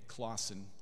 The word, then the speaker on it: Clauson: Clauson, spelled like Swedes, not Norwegians, C L A U S O N S O N.